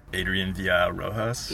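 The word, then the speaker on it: Adrián Villar Rojas: Adrian Villarrojas.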